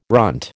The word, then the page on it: runt: American English